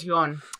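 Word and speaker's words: Youn: Yoon. Thank you. – Yoon. That I know is Yoon.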